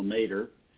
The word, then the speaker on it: mater: And, it turns out that the Ohio State University, my alma mater, that's how we pronounce it, Ohio Mater. It rhymes with potato.